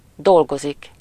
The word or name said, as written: travailler